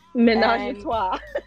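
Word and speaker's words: Ménage à Trois: And I just want to add that she has been a fave of mine since, I'm going to pronounce this wrong, Manage and Trois. – Menage and Trois.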